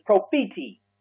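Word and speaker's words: prophetes (προφήτης): The next office that's identified there in Ephesians 4:11 is the prophet, or as correctly pronounced in the Greek, is prophetes.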